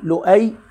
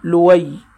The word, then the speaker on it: لؤي: قال: وقصي بضم القاف قصي، ولؤي بالهمز وتركه، يعني يمكن أن يقال فيه لؤي هكذا بالهمز، أو يقال لوي بترك الهمز، والياس بهمزة وصل يعني بدون نطق الهمزة.